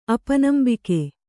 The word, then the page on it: ಅಪನಂಬಿಕೆ: apanambike